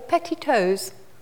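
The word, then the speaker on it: petitoes: The first one was petitos or petitos. – Petitos? – Yes. – How do you spell that? – So that is petit, as in French for small, and then toes. – T-O-E-S.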